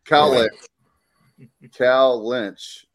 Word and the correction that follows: Kalinich: was – It's Kalinich.